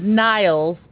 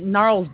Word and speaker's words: Gnarls: Dude, I wasn't sure what you were saying, because at first I thought it was Niles, and then I was like, well, no, it's not. Am I hearing Gnarls? I was trying to pronounce it as much as possible. – I read it different in my mind. Now that you say Gnarls Barkley, I'm like, oh, clearly.